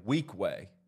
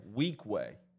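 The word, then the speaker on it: Weequahic: This is how you pronounce it, okay? So do not try to pronounce it any other way. Weekway. Weekway. Don't spell it out. Don't read it. Don't look at it.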